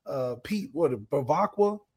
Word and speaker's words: Bevacqua: Hopefully, Bevacqua, is that how you say it? – You got it.